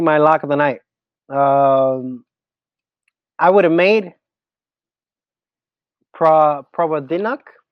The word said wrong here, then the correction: Provodnikov: How do you pronounce that? I forget. – Oh, is that the Paul Jones? – Yeah. – Provodnikov? – Provodnikov. There you go.